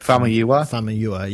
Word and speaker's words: Famuyiwa: Rick Famuyiwa.